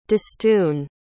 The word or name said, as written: distune